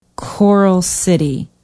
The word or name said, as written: Coral City